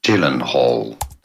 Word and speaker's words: Gyllenhaal: In English, in the US, pronounced as Gyllenhaal.